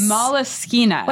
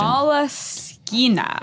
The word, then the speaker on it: Moleskine: Moleskine. – Let's just say the notebook. You know which one. – So you're saying that the engineer handed this to you and told us the correct pronunciation. – Moleskine.